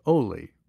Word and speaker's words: Oli: In June 2022, a friend invited me to a meet and greet with Olegario de Cantos VII, who goes by Oli, rhymes with goalie.